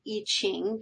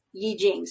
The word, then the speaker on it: Yi Jing: or you sometimes hear it pronounced Yi Qing, because that's how it looks like it's written. I believe the correct pronunciation, if we care, is Yi Jing.